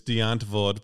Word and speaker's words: Die Antwoord: Do you know, I think the proper pronunciation is Deantwoord, but it's Deantwood, Die Antwood. – Yeah. – It reads as Die Antwood.